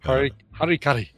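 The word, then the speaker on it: hara-kiri: Harry Carey or Harikari? How do you say it? – Harikari.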